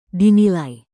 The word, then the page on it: dinilai: indonesian